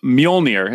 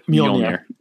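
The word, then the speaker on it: Mjölnir: So Mjolnir is how you say that, the name of the Mjolnir.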